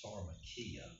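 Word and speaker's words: pharmakeia: The word for witchcraft in Greek is pharmakia.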